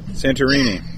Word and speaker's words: Santorini: Is it Santorini? – Santorini. – I don't know how you say it. – Santorini.